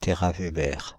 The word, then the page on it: téraweber: [te.ʁa.ve.bɛʁ]; Paris; France (Île-de-France); /te.ʁa.ve.bɛʁ/